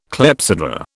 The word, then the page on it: clepsydra: British English